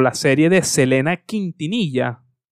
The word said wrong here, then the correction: Quintanilla: Quintanilla, y, lo tengo escrito y lo dije mal, de Selena Quintanilla.